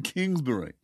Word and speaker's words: Kingsbury: I think you mispronounced her name. It's Kathleen Kingsbury.